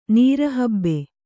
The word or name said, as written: ನೀರಹಬ್ಬೆ